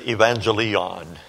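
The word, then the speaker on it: euangelion: the Greek that you would pronounce it like this, evangelion, the gospel.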